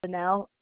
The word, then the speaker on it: Fennell: Okay, my name is Jennifer, Jennifer, and my last name is Fennell, F as in Frank, Ennell.